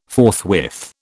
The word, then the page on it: forthwith: British English